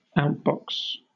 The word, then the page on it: outbox: British English